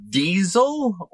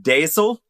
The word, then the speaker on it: deasil: It was also referred to as diesel or diesel, which is from the Scottish Gaelic language. – That's what now, I put in my husband's Jeep. – Yeah. – D-E-A-S-I-L.